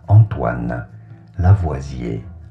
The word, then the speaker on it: Lavoisier: Bonjour, we are looking at how to pronounce the name of this French chemist, Antoine Lavoisier.